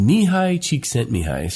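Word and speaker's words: Mihaly Csikszentmihalyi: His name is funny. It's pronounced Mihai Csikszentmihalyi.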